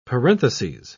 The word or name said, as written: parenthesis